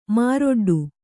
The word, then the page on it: ಮಾರೊಡ್ಡು: māroḍḍu